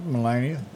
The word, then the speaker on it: Melania: Melania.